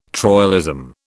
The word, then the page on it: troilism: British English